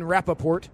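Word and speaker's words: Rapoport: The report was from Ian Rappaport, which I wish his name was something different. So it didn't rhyme with report.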